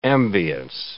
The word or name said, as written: ambience